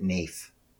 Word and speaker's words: naif: So the five descriptors are apprentice, coven member, mad, naif, or solitary adept. – Is that pronounced naif? I've always pronounced it naif. – I believe it is pronounced naif because it is related to naive, but I'm sure you're totally right.